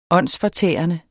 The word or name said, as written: åndsfortærende